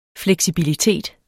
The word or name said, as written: fleksibilitet